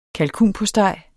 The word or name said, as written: kalkunpostej